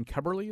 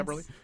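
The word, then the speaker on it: Keberle: Is it Ryan Keberly? Is that how you say it? – Yes. – Keberly? – Yes.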